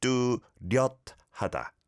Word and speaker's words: 뚜렷하다: There's a 시옷 받침, bottom consonant, on 려. So it's not 뚜렷하다, it's 뚜렷하다. 뚜렷하다. 뚜렷하다.